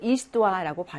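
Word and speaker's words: histoire: 이것도 이스토아라고 발음을 합니다.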